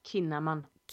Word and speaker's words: Kinnaman: Det är han som är på liksom, tillsammans med Melinda Kinnaman. – Kinnaman, oh my god. – Yes, jag fick rätta dig på ett uttal.